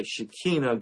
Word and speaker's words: Shekinah: The reason why I say Shekinah is that's the proper Hebrew way to pronounce that word. You can say Shekinah, but really it's pronounced when you talk to an Israeli. They say Shekinah.